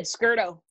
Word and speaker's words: Scurto: You did, Scurto.